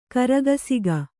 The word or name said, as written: ಕರಗಸಿಗ